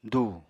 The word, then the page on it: dù: apatani